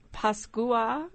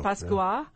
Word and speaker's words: Pascua: Instead of Feliz Navidad, which is what most people say, it's Pascua. Is that how you say it in Spanish? Pascua. – Yeah.